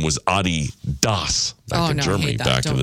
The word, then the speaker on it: Adidas: And apparently we're pronouncing Adidas wrong. It is supposed to be Adidas. – Oh, no, I hate that. – With a slight emphasis on the first syllable, not Adidas, stressing the second.